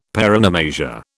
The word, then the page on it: paronomasia: British English